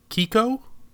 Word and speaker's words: Keiko: I believe it's pronounced Kiko, K-E-I-K-O, Kiko the Killer Whale.